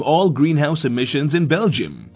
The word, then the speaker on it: Belgium: In Belgium. – あら、ベルギーは Belgium と発音するのね。